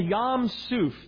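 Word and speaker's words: Yam Suf (ים סוף): Now in the Hebrew, the sea is called Yam Suf. That's how you pronounce it in Hebrew.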